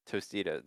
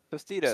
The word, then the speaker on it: Tostitos: I feel like you throw a D at it instead of a T. – Tostitos. – Say it again. That's a D.